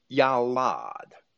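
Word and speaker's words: yalad (ילד): you'll find that it is translated from a Hebrew word, yalad, and that's spelled Y-A-L-A-D in English, yalad, which is Strong's number 3205, right?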